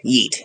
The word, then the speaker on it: yeet: Yeet. Yeet. Yeah. – Yeet. – No. Yeet. Yeah, don't... – Like eat, but with a Y at the beginning.